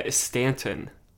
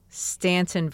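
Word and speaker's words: Staunton: Stanton. – Stanton, Virginia.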